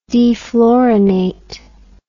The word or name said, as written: defluorinate